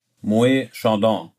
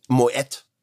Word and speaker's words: Moët: Wie spricht man Moe Chandon richtig aus? – Moe Chandon. Falsch. – Okay, Moet zack. – Chandon. – Moet. Ah, okay, tatsächlich, ja? – Tatsächlich.